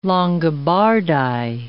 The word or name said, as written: Longobardi